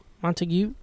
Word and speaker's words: Montague: It's north of like Montague. – Okay. – Montague. – Montague. – Silent G.